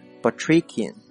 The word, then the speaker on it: Batrachian: 那么这个词啊，在这个托福考试当中或者是雅思考试当中这个阅读当中很常见啊，它呢读作 Batrachian， OK，拼成 B-A-T-R-A-C-H-I-A-N Batrachian，这个词就是形容词，叫做蛙类的或者叫无尾两栖类的。作为名词呢，指的就是无尾两栖类的动物。 OK，接下来来看一个词组 Batrachian position 啊， position P-O-S-I-T-I-O-N 这个词啊，